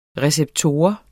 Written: receptor

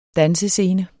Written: dansescene

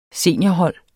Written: seniorhold

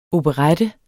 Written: OPERETTES

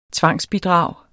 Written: tvangsbidrag

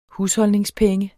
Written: husholdningspenge

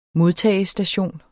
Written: modtagestation

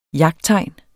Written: jagttegn

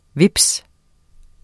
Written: HVEPSEN

